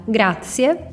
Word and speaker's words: grazie: Tchau para agradecer, você pode falar grazie, pode pronunciar esse ts exatamente como em pizza. Tente falar pizza, pizza. O ts fica ali atrás dos seus dentes, certo? Esse grazie tem o mesmo ts da pizza.